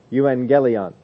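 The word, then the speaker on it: Euangelion (εὐαγγέλιον): But you'll get an NG sound when you get two G's put together. Always is that way. Whenever you see two G's, that's how it is pronounced. Euangelion.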